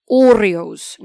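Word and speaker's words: hórreos: Then, when we talk about historic, they have what is called Oreos. Now, this is spelt with a H, so H-O-R-R-E-O-S.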